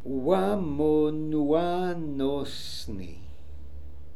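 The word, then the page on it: ooamonooanoossnian: Russian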